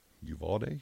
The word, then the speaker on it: Uvalde: Uvalde.